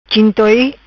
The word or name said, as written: Chintoi